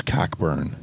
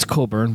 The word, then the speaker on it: Cockburn: Bruce Cockburn. – It's Colburn, but yeah. – No, it's Cockburn. – Okay. – What about that bitch Anne Murray? – Pronounce this. C-O-C-K-B-U-R-N.